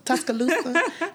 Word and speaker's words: Tuscaloosa: So this parties are being held in Tuscaloosa. Is that how you say it? – Yeah, when you have a place called, what's it, Tuscaloosa? – Tuscaloosa.